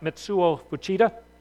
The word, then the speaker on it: Mitsuo Fuchida: This guy, I think his name is probably pronounced something like Mitsuo Fuchida.